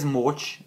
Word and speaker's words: Mocs: So that is Mocs, M-O-C-S, with a long O in Hungarian, yes.